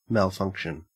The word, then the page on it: malfunction: Australian English